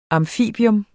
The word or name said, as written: amfibium